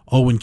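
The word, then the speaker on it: Eoghan: believes Owen Carey. Now, you look at the name, the way it's spelled E-O. G-H-A-N, I'm told Owen is the correct pronunciation.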